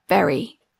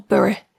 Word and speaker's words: Bury: who lives just north of Bury up in Lancashire, or Bury, if you say it correctly.